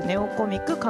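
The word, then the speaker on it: 弟コミック: 私、西脇圭がママ役で出演しております、アンドロイド端末用電子コミックアプリ、ネオコミック、鏡の国のキュルキュルが現在、前編、中編まで、 Google プレイストアで好評配信中です。ネオコミック、弟と書いてネオと読みます、ネオコミック、鏡の国のキュルキュルで、ぜひぜひ検索してみてください。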